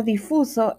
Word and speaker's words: difuso: Esta palabra difuso es una palabra llana, no se acentúa en la penúltima sílaba debido a que termina en vocal.